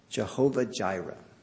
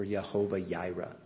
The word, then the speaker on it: Jehovah Jireh: And now in verse 14, and Abraham called the name of the place Jehovah Jireh, or Jehovah Jireh. The J is really not pronounced in the Hebrew, just we put the J there.